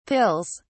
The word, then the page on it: pills: British English